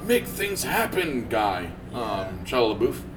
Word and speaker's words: Shia LaBeouf: Shia LaBeouf. – Shia LaBeouf.